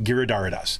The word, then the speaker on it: Giridharadas: There's a great book called Winners Take All by Anand Giridharadas, Giridharadas. That's it, Giridharadas.